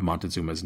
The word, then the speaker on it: Montezuma: Specifically, I want to discuss the pronunciation of his name you will find many different versions of Montezuma's name. Moctezuma is very common, for instance. I am going to use Montezuma, which is very much a Western pronunciation. It is what I have grown up saying, and it is what I am most comfortable using, so Montezuma it is.